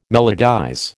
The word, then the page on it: melodize: American English